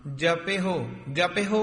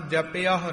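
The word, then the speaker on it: जपहु: रहो दोहा है शुद्ध उच्चारण करने, पीबहो अमृत सदा रहो हर रंग इथे विश्राम, जपे हो उच्चारण करना, जपया हो नहीं कहना, जिवे ज्ञानी जी ने उच्चारण किया जपे हो, जाप बोल के ते हाहे दी औंकड़ लाउणी, विच ऐड़ा नहीं लगन देना।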